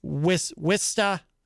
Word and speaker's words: Worcester: Wista is how you say it in Massachusetts.